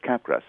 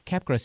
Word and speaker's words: Capgras: I'm not sure how it's pronounced. Capgras? – I think it's Capgras syndrome. – Yeah, Capgras syndrome.